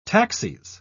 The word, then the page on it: taxis: British English